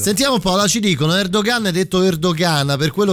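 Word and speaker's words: Erdoğan: potuto fare perché da persona insopportabile, insolente e presuntuoso, Erdogan so che non si pronuncia. – Ah, pensavo Erdogan. parlassi di te. Si pronuncia ehm, Erdogan.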